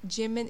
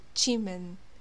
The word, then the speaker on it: Jimin: Also, like, it's not Jimin, it's like Chimin, you know? That's like the right pronunciation. Jimin is like the Western way of saying it.